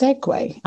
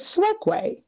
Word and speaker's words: segue: But they are both pronounced segue. I'm baffled. – Yes. – Thought it was swegue.